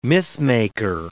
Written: mythmaker

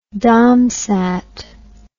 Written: domsat

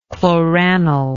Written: chloranil

